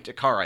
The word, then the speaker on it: Kara: Is like, as far as we can, Kara I think is how yeah. she pronounced it in the trailer.